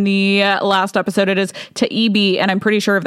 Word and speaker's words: Taibbi: It is Taibbi. Taibbi. I'm pretty sure that that is what it is.